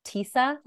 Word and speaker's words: Tisa: I was saving this for when we started, but my name is actually pronounced Tisa, like Lisa, but I have to explain that to everyone more than once. 85 to 90% of people pronounce it wrong. And then the minute I go, it's like Lisa with a T, they go, oh, okay.